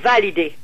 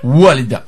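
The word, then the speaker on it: Walida (والدة): Par exemple, au lieu de dire Walida, qui veut dire une mère, la mère de famille, okay. – Ils disent Validé. – Validé. OK, ils vont prononcer v. plus V. OK. – Mais faut faire... Non, faut faire W, comme wou, ensuite W, ensuite... – Walida, OK.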